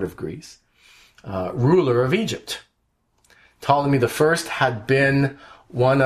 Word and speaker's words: Ptolemy: And Ptolemy, the P is silent, he was Ptolemy the Fifth.